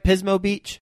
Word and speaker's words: Pismo Beach: Did I say that right? Pismo Beach? – You got it.